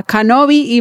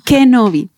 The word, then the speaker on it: Kenobi: Yo decía Kenobi y mejor dicho, casi me cuelgan. – Entonces es Kenobi.